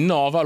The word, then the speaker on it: Nova: Allora, poi una cosa d'orgoglio, mentre noi Nova lo pronunciamo Nova. Senza, anche se c'ha l'accento.